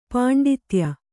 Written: ಪಾಂಡಿತ್ಯ

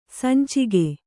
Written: ಸಂಚಿಗೆ

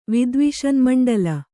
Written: ವಿದ್ವಿಷನ್ಮಂಡಲ

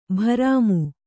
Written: ಭ್ರಮಿ

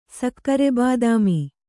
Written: ಸಕ್ಕರೆಬಾದಾಮಿ

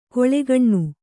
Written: ಕೀಳ್ಗಣ್ಣು

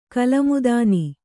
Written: ಕಲಮುದಾನಿ

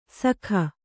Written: ಸಖ

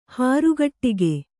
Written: ಹಾರಗಟ್ಟಿಗೆ

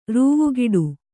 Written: ರೂವುಗಿಡು

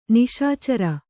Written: ನಿಶಿಚರ

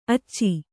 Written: ಅಚ್ಚೆ

